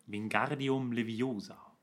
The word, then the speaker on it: Wingardium Leviosa: Wingardium Leviosa. – Ja. – Habe ich das jetzt richtig ausgesprochen? – Ja, hast du. Du hast nämlich das Gar schön lang gezogen.